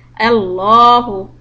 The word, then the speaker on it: Allahu: then we say Allahu, Allahu. But if you see a zair before Allah, name of Allah, then you pronounce it soft.